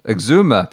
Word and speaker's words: Exuma: Corn Ferry Tour is back with the Bahamas Great Eczema. Exuma. People always make fun of my pronunciation.